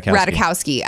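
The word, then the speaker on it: Ratajkowski: Radikowski? – I think that's good, yeah. – Radikowski.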